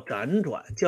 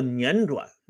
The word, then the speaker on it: 辗转: 毛岸英和毛岸青兄弟支付去苏联的费用啊，有的人呢又给我指出来啊，这个读音方面的失误，说不叫辗转，叫辗转。